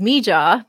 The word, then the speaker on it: MiJa: So the first pronunciation is Mija. Those are her first two letters of her first and middle name. But also, so if you spell that out, M-I-J-A, it's pronounced Mija.